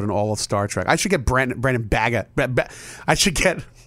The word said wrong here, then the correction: Braga: I can't pronounce his name. I should get Brandon. Braga. Thank you.